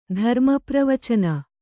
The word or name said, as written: ಧರ್ಮಪ್ರವಚನ